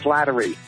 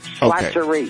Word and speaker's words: Slattery: By the way, last name is pronounced Slattery. – Slattery. – No okay. – L in there. Somebody misspelled my name. Slattery.